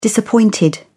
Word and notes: disappointed: British English

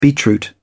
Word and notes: beetroot: British English

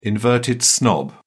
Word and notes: inverted snob: British English

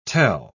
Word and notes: tel-: British English

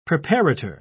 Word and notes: preparator: British English

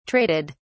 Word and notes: traded: British English

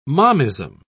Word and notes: momism: British English

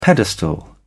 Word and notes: pedestal: British English

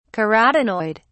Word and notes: carotenoid: British English

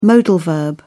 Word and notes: modal: British English

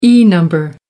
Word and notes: e number: American English

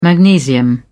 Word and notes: magnesium: British English

